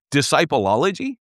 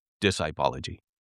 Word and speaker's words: Discipology: Discipleology? No. Discipology. That is how you pronounce it.